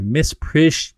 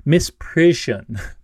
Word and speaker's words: misprision: And also the the weird word me and Mark talked about before, misprision, misprision, misprision. Uh, it's hard for me to pronounce that word. It's like "prision" and "mist" per- together.